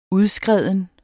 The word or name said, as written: udskreden